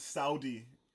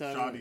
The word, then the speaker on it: Sade: That's Shoddy. – It's Shoddy. But no, how do you say it? – It's Shoddy. Shoddy. Yeah. Shoddy. Just like the jazz singer.